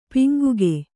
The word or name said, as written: ಪಂಗಿಗ